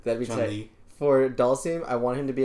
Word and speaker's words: Dhalsim: I was wanting fucking Dhalsim. Is that how you say it? – Yeah. – Dhalsim, yeah, he's dope.